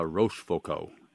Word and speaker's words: Rochefoucauld: The saboteur who posed as a nun, his name is Robert Jean-Marie de la Rochefoucauld.